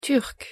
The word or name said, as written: turc